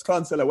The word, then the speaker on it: Clonsilla: He just said Clonsilla, and like that's weird. Like you said it wrong, but it's Clonsilla.